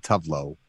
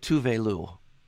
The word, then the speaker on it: Tove Lo: It's Tovelo, and it's not Tovlo. I know it's different. It's spelled T-O-V-L-O. – She pronounces it Tuvelu.